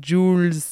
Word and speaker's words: Jools: Ça s'écrit J-O-O-L-S et ça se dit Jules.